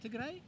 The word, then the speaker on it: Tigray: Is this this group here that you've got, is everyone from, how do you say it, Tigray, is that right? – Tigray, yeah.